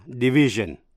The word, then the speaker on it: division: Những danh từ tương đương là division, chữ S đọc là J.